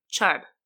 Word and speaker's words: Chun: Chun. – The G is silent. – It's C-H-U-N. I-N for in and Chun, C-H-U-N. – Sorry, I thought you said Chung the first time, so.